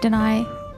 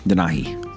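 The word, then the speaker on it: Denahi: Like that part when like Danai, how do you say his name? – Danahi.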